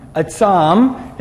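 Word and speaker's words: Atsam (עצם): It's spelled in Hebrew, it's spelled Ein, Tzadi, Mem. Atsam, and there it's atsum, and it means to have teeth and claws and to have like bones.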